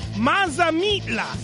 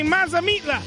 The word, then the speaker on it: Mazamitla: Mazamitla. Say it. Mazamitla.